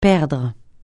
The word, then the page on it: perdre: francais